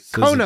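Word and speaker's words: Qo'noS: She pronounces it Kronos. – The right way.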